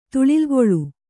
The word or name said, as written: ತುಳಿಲ್ಗೊಳು